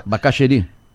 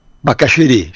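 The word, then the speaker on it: Bacacheri: É, Curitiba, mesma linha de Mafra, também está sendo quente, chegou ontem a 31 no aeroporto lá do, ba, do, né, do Bacaqueri, ba, Bachaqueri, que eu não sei como é que fala. Bacaxeri. Bacaxeri,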